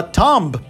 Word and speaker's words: tomb: A novidade aqui é que não se fala tomb para túmulo ou tumba. A gente pronuncia tomb. Exatamente. Tomb. Olha que estranho, né? A letra O fica com som de U e o B colocaram no arquivo.